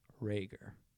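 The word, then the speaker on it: Reagor: I will have you know, I saw Jalen Rager tweet out that it's pronounced Rager.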